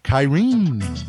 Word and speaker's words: Kyrene: Because we are going to have a link to the book Kyrene with a long E, and it is Chronicles of Kaelin, Book 1.